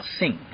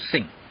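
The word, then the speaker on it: sin (שׂ): 你看它好像一顶皇冠啊，皇冠上有一颗钻石啊，钻石在左边读 sin 啊，sin 啊，你跟我读 sin，sin 好像英文的 sin。啊、是罪恶，是的啊，发音完全一样的，就是 sin 啊 sin。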